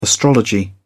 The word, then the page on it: astrology: British English